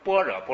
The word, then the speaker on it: 般若: 哎，但是呢，古人传，哎，僧伽，所以呢，我们就这个呢，就是一个读音无所谓，我们就尊重古例。哎，我们就读般若波罗蜜多。